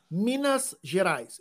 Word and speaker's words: Minas Gerais: Minas Gerais.